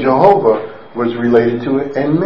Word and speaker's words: Jehovah: We understand and know, based on the Hebrew grammar and notation and their pointing system and vowel system and so forth, that that name should be pronounced Jehovah.